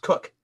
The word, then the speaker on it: Koch: My legal last name is K O C H, pronounced Cook, which really easy to screw up and sound like a dirty word.